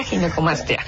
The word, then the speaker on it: ginecomastia: Pues muchas gracias, y sí, definitivamente creo que es una, para empezar la palabra, le decía, ¿cómo se pronuncia? Ginecomastia, ginecomastia. – Sí, exacto, ginecomastia.